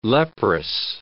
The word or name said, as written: leprous